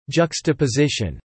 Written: juxtaposition

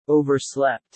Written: overslept

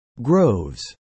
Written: groves

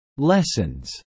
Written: lessens

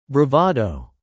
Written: bravado

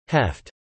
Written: heft

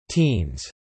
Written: Teens

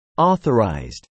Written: authorized

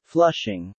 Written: Flushing

